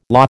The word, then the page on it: lah: American English